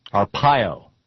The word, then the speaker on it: Arpaio: Arpaio is how it's pronounced. Arpaio. Maricopa County Sheriff Joe Arpaio.